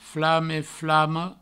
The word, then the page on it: flame, flama: flame, flamo; Provençal